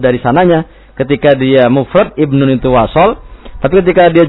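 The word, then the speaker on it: ibnun (ابن): Betul, ibnun. Iya. Ini uniknya kalau ibnun itu ketika dia mufrad hamzahnya hamzah wasal. Tapi ketika jamak hamzahnya qat'i.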